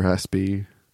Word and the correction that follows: ASCII: ASCII, yeah.